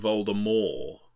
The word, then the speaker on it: Voldemort: Of course, you're supposed to pronounce his name Voldemort. The T is supposed to be silent, isn't it? – Yes, I know, but they didn't do it in the movies, so.